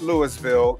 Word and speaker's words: Louisville: And I said Louisville, ladies and gentlemen. It's not Louisville. It's not Louisville. It's Louisville. Is that right, Lawrence? I just want to make sure we're saying it right here.